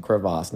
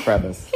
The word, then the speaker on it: crevasse: That is the correct pronunciation, crevasse, not crevice.